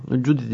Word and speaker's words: Cudi: Bir de öteden beri Cudi Dağı'nda top atışları nedeniyle, Cudi mi? Nasıl Cudi okunuyor bilmiyorum. Cudi. Ama iğnenin üzerinde Cudi diye okunuyor.